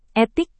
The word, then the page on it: etik: indonesian